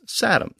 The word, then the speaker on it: satem: And in Sanskrit, the word was satum, spelled S-A-T-E-M.